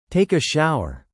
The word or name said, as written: take a shower